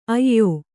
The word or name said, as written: ಅಯ್ಯ